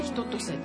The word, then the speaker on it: 一歳: では最後聴いていただくのはジャスミンさんで、人とせだっけ、人とせ、一歳と書いて人とせと読む、素敵な曲を聴きながらお別れしたいと思います。また来月も必ず聴いてください。ありがとうございました。